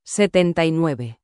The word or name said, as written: 79